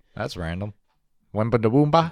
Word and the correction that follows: Wembanyama: Wambi Heba. – I don't know how to say his name. – Victor Wembanyama. – There you go.